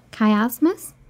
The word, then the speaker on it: chiasmus: Lundgren used a system of reading called, I don't know how to pronounce this, chiasmus? – That sounds right.